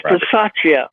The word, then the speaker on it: Bisaccia: Rich Bisaccia. – Bisaccia.